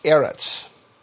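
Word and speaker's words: Eretz (ארץ): But what I'm interested in is this little, in Hebrew, a three-letter word that we pronounce Eretz.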